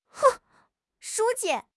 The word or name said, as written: 书记